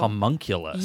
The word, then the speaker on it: homunculus: H-O-M-U-N... C-U-L. – Oh, homunculus.